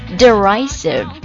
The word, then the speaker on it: derisive: 我们做出的这个表情啊，发出的声音啊，或者说出的话语，嘲弄的怎么读呢？ Derisive, D-E-R-I-S-I-V-E, derisive。